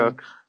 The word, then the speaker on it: Koch: And is it Jim Koch or Jim Koch, however you pronounce his last name, Cook.